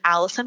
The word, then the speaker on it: Allyson: Yeah, you can find me on Instagram and on TikTok under my name, which is Allison, but it's spelled with a Y. So A-L-L-Y-S-O-N. And my last name is Leet, L-E-E-T.